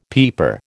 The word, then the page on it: peeper: American English